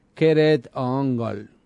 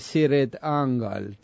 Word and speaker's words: Cirith Ungol: La forma correcta de pronunciar Siri Dangol es Kered-ongol. Kered-ongol. Pero la banda pronuncia con una C suave Siri-ongol. Tiene como más onda, me parece.